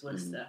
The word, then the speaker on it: Worcester: It is Worcester. It looks like Worcester, but it's Worcester.